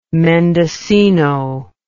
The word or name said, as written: mendocino